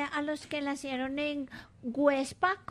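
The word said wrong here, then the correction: Güepsa: Perdón, Huebsa. Websa. ¿Ah? Con diéresis.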